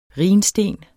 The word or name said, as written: rhinsten